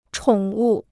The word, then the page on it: 宠物: chǒng wù